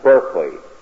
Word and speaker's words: Berkeley: Bishop Berkeley, or as the word is now pronounced, Berkeley.